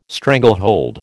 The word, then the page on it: stranglehold: American English